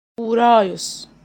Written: Ouraeus

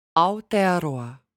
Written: Aotearoa

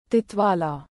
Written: Titwala

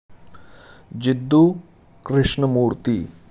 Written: J Krishnamurti

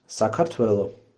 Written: Georgia

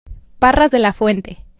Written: Parras de la Fuente

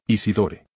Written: Isidore

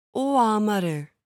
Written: Oamaru

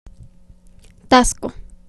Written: Taxco

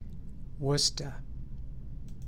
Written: Worcester

